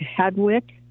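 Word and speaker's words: Hadewijch: You got to help me with, is it Hadowich? Is that how it's pronounced? – Yeah, some people pronounce it differently. I think it's more like Hadwick. – Okay. – I think it's a harder sound, but you're okay.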